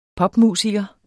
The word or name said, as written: popmusiker